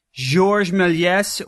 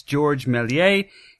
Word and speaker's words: Georges Méliès: This week for my Forgotten Superheroes of Science segment, I'm going to talk about Georges Méliès, or you could also pronounce it, I guess, Georges Méliès, who was a French filmmaker and illusionist who made the first science fiction film and is considered the father of special effects.